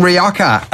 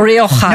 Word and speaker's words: Rioja: Somebody actually pronouncing Rioja correctly on Movies and Booze. What's the word coming to? – That's because my co-host of the podcast, Wine the Long and Short, is Antonia Dominguez, and she's Spanish. And if I don't say it correctly, I get in trouble.